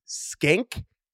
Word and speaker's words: Schenck: More specifically, Aaron Judge's personal hitting coach, a gentleman named Richard, I believe it's pronounced Skank. – I think it is Skank. Yeah, it looks like Shank. – S-C-H-E-N-C-K, Richard Skank, also known as Teacher Man online.